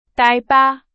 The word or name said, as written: 大巴